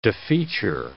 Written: defeature

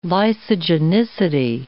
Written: lysogenicity

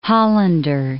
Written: Hollander

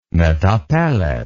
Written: metapelet

